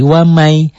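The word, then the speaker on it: wamai (وَمَنْ): Dibacanya N-nya jadi hilang. Karena nun mati ketemu Y, N ketemu Y, N-nya hilang jadi Y, jadi wamai.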